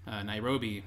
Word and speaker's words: Nairobi: And there's a guy in Nairobi.